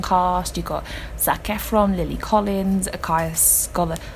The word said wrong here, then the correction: Scodelario: I never pronounce her name right. Akaya Scodelario.